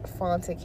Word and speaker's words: Fanta: You can follow me on Facebook as well at Fonta Kante, and it's spelled F-A-N-T-A, last name K-A-N-T-E.